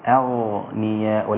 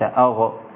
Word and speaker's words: أغنياء: الغين والهمزة أنت حركتيها وهم ساكنة إذا وقفت طبعا على الهمزة سكنت من أجل الوقف والغين أيضا ساكنة مفخمة فيكون النطق بها أغنياء وليس أغ لا أغ يبقى أنا حركت الغين وهي ليست محركة ولا مقلقلة إنما هي أغنياء